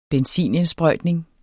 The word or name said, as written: benzinindsprøjtning